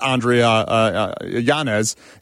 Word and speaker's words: Yanez: We saw that on full display last week when he knocked out Andre Yanez, if you will. Is that his name? I totally butchered on that. Yes. – Okay, it is.